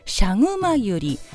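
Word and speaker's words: 赤熊百合: ちょっとモコモコした印象もあって、クマさんの体毛のような感じと似ているから、日本名シャグマユリ、赤いクマのユリと書いてシャグマユリと読むんですけれども、という日本名がついたのかなと思ったのですが、兄はからんや。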